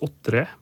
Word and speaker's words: otre: Det er mulig det skal uttales otre, men iallfall O-T-R-E, som betyr å, å kjøre eh, attover, eller å få til å, å ga attover.